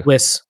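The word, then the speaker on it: Wyss: Wiss.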